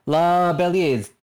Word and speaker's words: La Belleza: We both use she, her pronouns, and the playbook I will be playing today is La Belleza? – Belleza. – Belleza, yeah. – Yes, that is how you pronounce it.